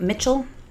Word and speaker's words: Michel: And then, how do you spell that? – Like Mitchell. – Oh. – M-I-C-H-E-L. – Michiel? I guess.